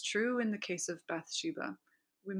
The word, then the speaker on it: Bathsheba: So Bathsheba think the proper pronunciation is Bathsheba.